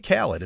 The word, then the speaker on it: Khaled: DJ Khaled, is that how you say his name? – Yes.